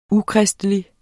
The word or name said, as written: ukristelig